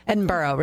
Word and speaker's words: Edinburgh: Edinburgh, really, is how you pronounce it.